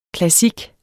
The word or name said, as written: klassik